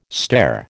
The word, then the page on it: stare: American English